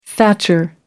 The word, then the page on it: thatcher: American English